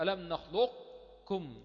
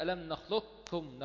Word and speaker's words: نخلقكم: يبقى المقصود بالوجهين هنا الوجه الأول الإدغام الناقص وهو ذهاب الحرف وبقاء الصفة اللي هي الاستعلاء. يبقى الخلف بنخلقكم يعني أقرأها بإدغام ناقص. ده وجه أولاني. أقولها إزاي؟ أجيب صفة القاف وبعد كده أنطق الكاف. ألم نخلقكم، نخلقكم. الوجه الثاني الإدغام الكامل وهو ذهاب الحرف والصفة، يعني ألم نخلقكم، نخلقكم من ماء مهين، نخلقكم من ماء مهين. أذهب الحرف والإيه والصفة، يبقى نخلقكم فيها وجهين إدغام كامل وإدغام ناقص.